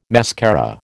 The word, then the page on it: mascara: American English